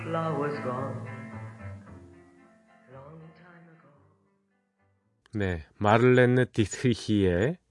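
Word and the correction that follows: Marlene Dietrich: 발음이 좀 힘듭니다. 마를렌느 디트리히의 예, 노래였습니다.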